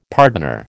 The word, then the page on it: pardoner: American English